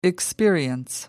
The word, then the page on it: experience: American English